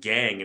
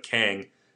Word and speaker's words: Kang: They signed a shortstop from Korea, Jung-ho Kang, though I think I've been told it's pronounced Gang instead of Kang.